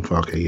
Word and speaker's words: Farke: is that how you say his name? Farke? I think he went down with Norwich. – Norwich manual. Mm-hmm. Daniel Farke, yeah.